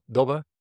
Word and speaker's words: Dobbe: Ton Dobbe, that's the pronunciation.